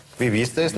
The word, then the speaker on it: vivisteis: А куда ударение vivisteis? – Vivisteis на предпоследнем.